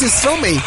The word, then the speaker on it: Stromae: Que no supo pronunciar Stromae. Es que es Stromae.